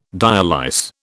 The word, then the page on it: dialyse: British English